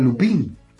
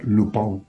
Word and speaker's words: Lupin: El nombre en español es Lupin, porque se escribe Lupin, pero en francés se pronuncia Lupin.